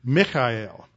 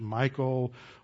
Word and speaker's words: Michal: A lot of people have trouble pronouncing this name, spelled M-I-C-H-A-L. You look at it in English, you want to pronounce it Michael, or some people have tried to pronounce it Michelle.